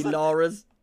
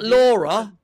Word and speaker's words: Lara: Her name's Lara Croft. It's not Laura.